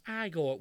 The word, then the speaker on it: Igor: But he must be Igor. No, it's pronounced Igor.